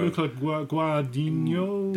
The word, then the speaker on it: Guadagnino: Luca Guadagnino.